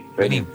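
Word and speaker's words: Benín: Aparte un partido tan difícil como con Benin, calculo que lo que Rater... – Benin. Con acento en la I. – Benin.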